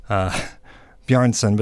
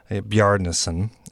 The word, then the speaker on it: Bjarnason: That was the Icelandic composer, who I usually call Daniel Bjarnason, but that's not right, as they pronounce the first syllable. It's Daniel Bjarnason,